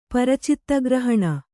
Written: ಪರಚಿತ್ತಗ್ರಹಣ

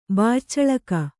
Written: ಬಾಯ್ಚಳಕ

